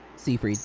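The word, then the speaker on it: Seyfried: Siegfried.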